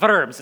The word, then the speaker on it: Worms: This is the great speech by Martin Luther when he's sitting at the Diet of Worms, which if you don't say it like that is literally the it's technically the Diet of Worms, but if you say it in English, it's the Diet of Worms, which is a good way to remember.